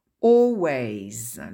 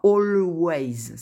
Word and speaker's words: always: Usually, usually, always. Le L ne se prononce pas, on ne dit pas always, c'est impossible à dire d'ailleurs.